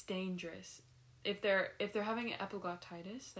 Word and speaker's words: epiglottitis: Let's start with epiglottitis, shall we? – Yeah. – Okay. So this is... – How do you say it? – Epiglottitis. – Epiglottitis. – Mm-hmm. – Epiglottis. – No.